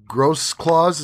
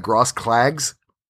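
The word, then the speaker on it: Grossklaus: And then Mancrafting, Chad Gross Claws is how you pronounce his name. It looks like Gross Clags. Gross Claws, I asked him.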